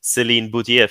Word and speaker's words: Céline Boudier: Och det har vi faktiskt förra veckans vinnare, Céline Boudier från Frankrike, som vann även den här veckan. Snacka om att ha förändrat sitt liv lite grann på 2 golfveckor. – Snacka om bra uttal, där.